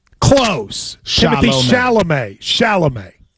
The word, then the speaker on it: Chalamet: Shama. It's a lot of like M's and L's in his name. – You never once managed in that segment to say it correctly. You said sham, you said charm, you said...